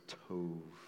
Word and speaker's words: tov: And Jake, in his message last week, he had mentioned that the Hebrew word tov, which was very good, tov, you've pronounced it completely right, tov, right?